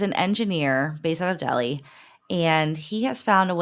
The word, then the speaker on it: Delhi: So this next story comes to us out of, I think, is it pronounced Delhi? D-E-L-H-I. I know here on the west side it's Delhi, but I think in India it's Delhi. Is that right? – I think so, yeah.